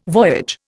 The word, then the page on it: voyage: Indian